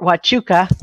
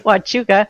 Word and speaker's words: Huachuca: First of all, you pronounce it Fort Huachuca. – Huachuca. Okay. – Huachuca. – Thank you.